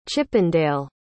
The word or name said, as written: chippendale